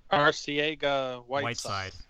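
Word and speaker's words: Arcega-Whiteside: Arcega Whiteside.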